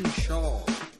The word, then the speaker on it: Schaal: Just wanted to pop in real quick to just to let everybody know that some of us here understand that it's actually pronounced Kristen Schaal and Kurt Braunohler.